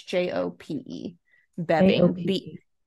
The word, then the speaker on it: Joop Beving: Is that how I pronounce his name? Yeah, it's Joop or Joop. It's Jope Beving, Beving.